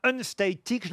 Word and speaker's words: Unstatic: Manu Katché et son album Unstatic, je le prononce bien ? – Parfaitement.